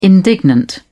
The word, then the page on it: indignant: British English